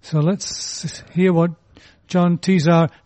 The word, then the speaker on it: Tesar: And John Tesar, spelled T-E-S-A-R, but pronounced as though he's involved with beverages rather than with food, decided he would write his first book on the subject of steakhouses, but steakhouses with a difference.